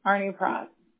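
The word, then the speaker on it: Arnoux-Prost: A-R-N-O-U-X, and then there's a dash, and then P-R-O-S-T, Arnie Frost. – C-R-O-S-T.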